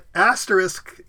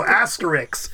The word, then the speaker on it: asterisk: But she, for years, she would get asterisk confused with Asterix, the cartoon character.